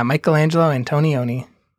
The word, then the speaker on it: Michelangelo Antonioni: Okay, so how do you say his name? – Sorry, my headphones fell off my head, and I was trying to recover really quick. Michelangelo Antonioni, is that who you're asking? – Yeah, is that how you say his name? – Yeah, Michelangelo Antonioni.